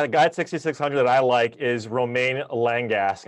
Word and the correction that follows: Romain Langasque: and I butchered his, his name. He's uh, French, so I might leave that to you to pronounce his name. – Oh, He's oh, a guy that. Oh, you mean my main man, Romain Langasque? There we go. – That's how you say it.